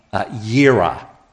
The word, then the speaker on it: Jireh: But it's in Genesis 22:14, and it's Jehovah Jireh. You heard that? Sometimes that might be pronounced Yahweh Yireh. Don't worry about your pronunciation.